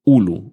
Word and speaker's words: Hulu: se va a estrenar en la plataforma Ulu Hulu con H muda al principio,